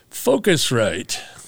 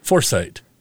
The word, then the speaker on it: Focusrite: And speaking of hardware, and you laughed at me, but I had to put a pronouncer here. Focusrite. And I have to, because every time I look at it, I see Foresight.